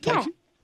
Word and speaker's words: Ticonderoga: You're calling from Ticonderoga? How do you say it? – Ticonderoga.